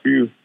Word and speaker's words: Few: And I think the 3 years at Gonzaga, you know, under Coach Mark Few.